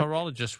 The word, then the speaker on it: pterologist: A pterologist with a silent P.